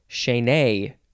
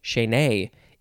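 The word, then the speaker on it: chaîné: Also the synonym fetter, F-E-T-T-E-R. Next is, I think it is pronounced chainay. You gotta emphasize the nay, chainay. It is spelled C-H-A-I-N-E.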